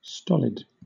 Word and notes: stolid: British English

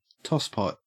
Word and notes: tosspot: Australian English

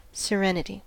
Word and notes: serenity: American English

